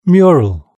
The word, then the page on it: mural: British English